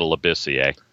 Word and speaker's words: Labissiere: I know I said Labassier instead of Labissiere.